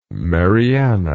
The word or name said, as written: Marianna